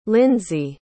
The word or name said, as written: lindsie